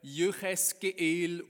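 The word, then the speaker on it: Jücheskeel (יחזקאל): Hebräisch spricht man den Namen etwa Jücheskeel aus.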